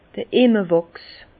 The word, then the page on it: d'r Immerwàchs: Bas Rhin; Herrlisheim